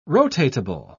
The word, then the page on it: rotatable: British English